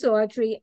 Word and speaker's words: Audrye: So my name is Audrye. I spell it A-U-D-R-Y-E. So if you see it spelled that way, that is the correct way to spell it.